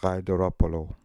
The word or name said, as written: Gaidoropoolo